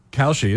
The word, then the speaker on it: Kalshi: Did I pronounce that online site correctly? Kalshi? – Kalshi is correct, yes.